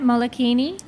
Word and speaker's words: Molokini: Molokini? – Yeah, yeah, yeah.